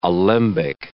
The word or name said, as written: alembic